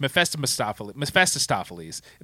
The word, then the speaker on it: Mephistopheles: That is the way it should have been, but it's Mephistopheles. There's extra syllables in here, they say. They slam together.